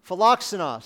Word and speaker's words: philoxenos: Philoxenos. So there you go. That's how you pronounce that word, philoxenos.